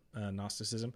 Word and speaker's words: Gnosticism: The point is that Gnosticism, spelled with a G, Gnosticism, Gnosticism,